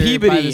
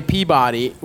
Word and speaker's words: Peabody: Manchester Peabody, huh? Yeah. And I said it right, too, motherfuckers. – Yeah, yeah. – Peabody? I used to say Peabody, which makes more sense.